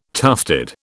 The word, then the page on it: tufted: British English